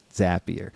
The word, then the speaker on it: Zapier: Couldn't have done it without Zapier. We all know how to pronounce it now too, at least. Yeah, makes you happier. Yeah, couldn't have done it. So I don't know. – Zapier makes you happier.